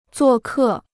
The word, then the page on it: 做客: zuò kè